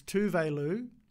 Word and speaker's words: Tove Lo: It wasn't until she actually came to Australia, did an interview, and they were like, oh, are we saying your name right? She's like, no, you're the mother butchering it. It's Tove Lo.